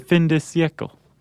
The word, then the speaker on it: fin de siècle: And all of it came from a left-wing Quaker intellectual at the turn of the last century, the fin de siècle. – Yeah. – I'm saying that right. – Yeah, sure. – Okay. – I think it sounds right.